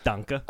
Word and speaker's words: Danke: Is that how you say it? – Oh, my God. No. Wait, let's try again. How do you say, like, hey? – Just Danke. – Oh, Danke.